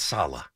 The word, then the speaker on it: Saleh: Robert Salah, the worst, worst. Right. – He's going into his third year. Can we get his name right? It's Saleh. I mean, let's at least get the guy's name right.